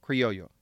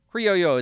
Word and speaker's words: criollo: That is spelled C-R-E-O-L-E. In English, this is pronounced Creole, but the word in Spanish is pronounced criollo, and thus I will be going with the Spanish pronunciation in respect to the context.